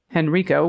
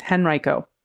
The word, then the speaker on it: Henrico: Just a minor pronunciation of the place Henrico, which you pronounced Henrico, which I just did. It's actually Henrico. Okay, so thank you. This is Henrico.